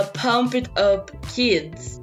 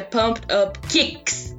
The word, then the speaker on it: Pumped Up Kicks: A música que até então eu pronunciava Pumped Up Kids, que eu descobri agora que é Pumped Up Kicks, de chutes.